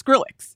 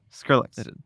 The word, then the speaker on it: Skrillex: Well, it's Skrillex. – Yeah. – Skrillex. – Yeah. You're Skrillex.